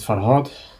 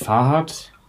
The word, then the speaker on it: Farhot: So mit euch ist dann normal, ihr sagt Farhad. Wenn ich mich irgendwo vorstelle, sage ich Farhad.